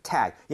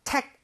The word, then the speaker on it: tag: So tag. 얘는 tag 아닙니다. G는 CK라는 스펠링보다 부드러워요. – 그렇죠. – 그렇게 되면 tag 하지 마시고요. Tag. – Tag. – Tag. 이런 느낌으로 부드럽게 한번 해보세요.